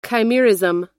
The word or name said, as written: chimerism